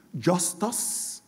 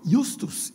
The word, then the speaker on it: justus: The adjective justus, that maybe you would pronounce justus in English, but justus is the Latin pronunciation. G-U-S-T-U-S, you can see just in it.